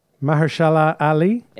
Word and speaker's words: Mahershala Ali: Actor in a supporting role, Mahershala Ali.